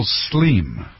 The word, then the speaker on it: Slim: Here's Mexican mogul Slim. Looks like Slim, S-L-I-M, but using the Spanish pronunciation.